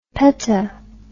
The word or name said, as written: peta